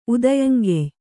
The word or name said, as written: ಉದಯಂಗೆಯ್